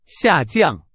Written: 下降

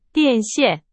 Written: 线

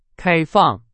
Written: 开放

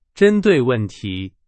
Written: 针对

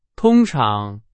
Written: 通常

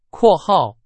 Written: 括号

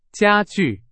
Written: 家具

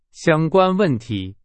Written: 相关

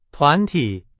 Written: 团体

